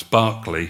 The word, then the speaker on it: Berkeley: In fact, from the Appomattox River to the Chickahominy, 140 settlers perished that day, including men and women at this plantation I pronounce Barclay from uh, Barclay, Gloucestershire, uh, the sponsors of this plantation at uh, Berkeley 100, where Captain George Thorpe resided.